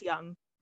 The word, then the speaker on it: Jung: Isn't it Young? – Yes, put, something like that. Jung, Jung, maybe? Yeah. – I think they pronounce it as Young. – Young, yes. So interesting.